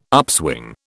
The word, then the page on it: upswing: British English